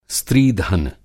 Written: स्त्रीधन

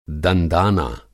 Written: दंदाना

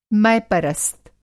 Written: मैपरस्त

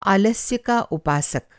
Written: उपासक

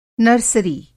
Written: नर्सरी

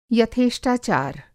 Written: यथेष्टाचार

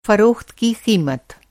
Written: फ़रोख़्त

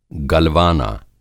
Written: गलवाना

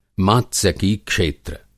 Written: मात्सयकी-क्षेत्र

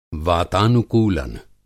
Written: वातानुकूलन

